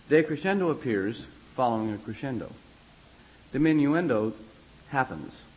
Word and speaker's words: diminuendo: D-I-M-I-N-U-E-N-D-O, diminuendo. Oh, diminuendo. Sound it out, spell it. Phonics and all that, you know? One nice thing about Italian, it's spelled just the way it sounds. There's not all the added attraction words like in English, or added attraction letters like in English. It's every vowel sound has a vowel. And every vowel has its own sound.